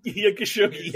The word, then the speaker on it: Khashoggi: Khashoggi, yes.